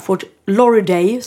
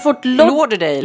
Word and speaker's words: Lauderdale: Jag ber så mycket om ursäkt till Jessica Wahlgren som har lyssnat på vår podd och säger att jag kan inte uttala Fort Lauderdale, som jag har sagt. – Det kan du inte. – Det heter tydligen Fort Lauderdale.